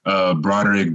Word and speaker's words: Broderick: My name is Broderick Dunlap. You spell that with B-R-O-D-E-R-I-C-K. Last name Dunlap, D as in dog, U-N-L-A-P.